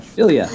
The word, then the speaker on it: Ophelia: Yes. Ophila. Ophila? – Wow. – Your name wrong. Ophelia? – You just fail at words. – Ophelia. That's right.